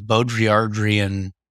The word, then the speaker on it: Baudrillardian: And so what the left hemisphere does is it can get into this place It's like this Baudrillardian, that's how you say it, hyper reality, where it's just a hall of mirrors and just abstractions off of abstractions after abstractions.